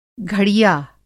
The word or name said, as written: घड़िया